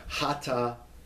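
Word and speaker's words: Hattaavah: So when you see, there's a word we'll see when we look at this section in a minute, but Hata-Ava. All right, there's a place name, and it's pronounced Hata, so pronounce the two consonants together, Hata, put a little break there, and then Ava, Hata Ava.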